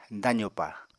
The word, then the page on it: dazopa: apatani